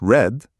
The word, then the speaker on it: read: Read, pronounced red.